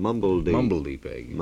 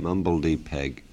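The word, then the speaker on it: mumblety-peg: And your pronunciation was right, according to this gentleman. Mumbledy Peg. Mumbledy Peg.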